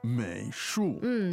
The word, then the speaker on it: 美术: 美术 third and fourth tone.